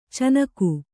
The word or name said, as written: ಚಿಣ್ಣಿಗ